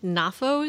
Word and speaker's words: Knafo: It's written and directed by Julien, I think, Knafo is how you say it, right? – Mm-hmm.